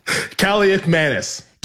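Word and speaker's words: Kaliakmanis: It was 6-0 when Kalikamanis. How do you say it? – Kalikamanis.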